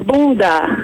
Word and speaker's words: bunda: Well, the word is actually not bunga, it's bunda.